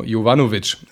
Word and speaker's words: Jovanovic: Der wird ein bisschen wie Jobovic ausgesprochen. Also Jovano, Jovanovic. Gianni Jovanovic war da.